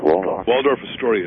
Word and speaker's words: Waldorf Astoria: Waldorf Astoria.